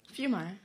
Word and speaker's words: Fiumara: Did I say it right? – Fiumara. – Yeah, Fiumara. See, she was right.